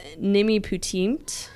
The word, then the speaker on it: Nimipuutimt: I think it's Nimiputimt. N-I-M-I-P-U-U-T-I-M-T.